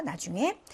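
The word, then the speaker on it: 나 (да): 자, 그래서 yes라고 하는 말을 러시아로 나 이렇게 하죠.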